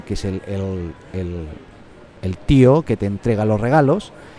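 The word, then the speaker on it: tió: Eh, es tío, es como el acento en, la O.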